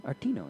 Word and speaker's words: Artino: Would you say it like that? – Artino, yeah.